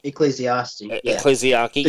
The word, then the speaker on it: Ecclesiarchy: Ecclesiarchy, yeah.